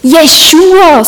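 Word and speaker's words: Jeshua (ישוע): Jeshua, so spricht man es aus auf Hebräisch.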